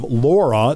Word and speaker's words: LARA: Another thing that the director of Laura, it's not Lara, she pronounces it Laura, I've always been saying Lara, and that's the Department of Licensing and Regulatory Affairs.